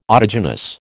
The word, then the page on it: autogenous: American English